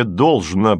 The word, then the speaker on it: должно: Не должно быть, с ударением на букву «О» твердо сказал Лисицын.